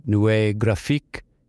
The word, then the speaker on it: Nouveau Graphique: Firstly, it's pronounced Nouveau Graphique. In English, I believe you call it New Graphic Design.